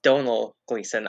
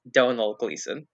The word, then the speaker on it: Domhnall Gleeson: And it stars Domhnall Gleeson? Is that how it's pronounced? It's Domhnall Gleeson.